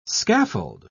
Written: scaffold

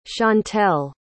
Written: chantelle

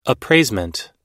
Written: appraisement

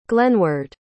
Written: glenward

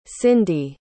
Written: cindy

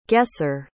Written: guesser

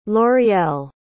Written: l'oreal

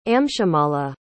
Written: amshumala

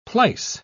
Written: plies

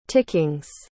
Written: tickings

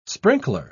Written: sprinkler

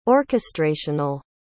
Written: orchestrational

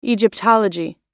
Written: egyptology

